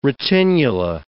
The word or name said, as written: retinula